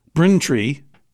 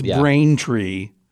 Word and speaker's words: Braintree: Dedham is good, but Braintree is pronounced Braintree. It's not pronounced like Brintree or whatever. It's Braintree.